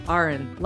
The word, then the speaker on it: Arehn: but it is Arehn, like Lauren, without an L.